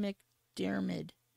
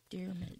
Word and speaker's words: McDiarmid: How would you pronounce it? – Ian McDiarmid. – There you go. – McDiarmid.